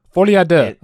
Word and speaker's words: Folie à deux: Uh, Folie à deux. That's how you pronounce it.